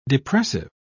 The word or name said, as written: depressive